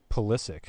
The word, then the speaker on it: Pulisic: Well, he's come out and said he wants to be called Pulisic. – Pulisic. All right. – Yeah, which is like the Americanized pronunciation.